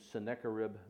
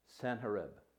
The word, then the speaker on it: Sanherib: We pronounce his name Sennacherib, but his name is actually pronounced Sanherib. Sanherib is the way it's pronounced in the Aramaic.